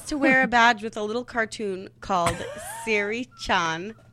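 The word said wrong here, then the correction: Seiri-chan: And it's pronounced how? – Sari Chan. S-E-I. Sari Chan.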